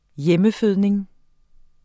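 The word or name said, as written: hjemmefødning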